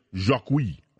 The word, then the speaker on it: Jocqui: His brother's name is Jacqui, if I'm pronouncing it right. J-O-C-Q-U-I, Jacqui.